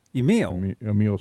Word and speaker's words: Emile: Is it Emile? Is that how you say your name? Emile? – Emile Smith.